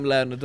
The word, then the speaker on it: learned: I am learned. That's past tense with an odd pronunciation of learned. Learned.